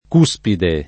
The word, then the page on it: cuspide: lingua italiana